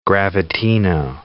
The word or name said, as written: gravitino